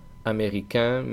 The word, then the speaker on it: américain: Primero, los que acaban en AIN, que se pronunciará en el masculino. Por ejemplo, américain, mexicain, africain, marocain, cubain. El femenino, en cambio, no tiene ese sonido nasal.